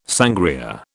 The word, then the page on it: sangria: British English